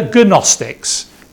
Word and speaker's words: Gnostics: These are the Gnostics, the Gnostics, the silent G.